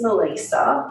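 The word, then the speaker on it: Melisa: Quigley is because my name is Melissa, not Melissa, and it's spelt with one S, not two S's.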